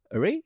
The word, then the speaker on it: awry: Awry? That's how you say it? A-W-R-Y? – Awry.